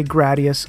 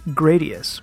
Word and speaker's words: Gradius: Some people say Gradius, others say Gradius.